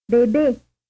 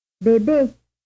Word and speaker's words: bébé: Bébé. Bébé. Bébé. – This word is pronounced here with an unstressed vowel 8 followed by a stressed vowel 8.